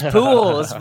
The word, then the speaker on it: Pujols: I'm like, "No, that's how you say his name. It's Pujols."